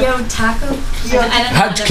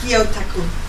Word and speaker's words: Gyotaku: Gyotaku? – Can you just spell it? – G-Y-O-T-A-K. Gyotaku.